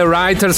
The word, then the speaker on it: Rytr: Come writer, scrittore, ma si scrive R-Y-T-R.